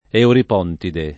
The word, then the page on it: Euripontide: italiana